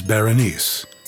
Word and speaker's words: Berenice: Oh, a word about the pronunciation of the main character. We chose Berenice.